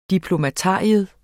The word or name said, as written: diplomatarium